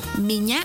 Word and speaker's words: меня: Меня зовут. Por ejemplo, меня зовут. Esta palabra se escribe меня. M-I-Ñ-A. Меня. Зовут. Z-A-V-U-T.